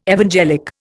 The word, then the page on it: evangelic: Indian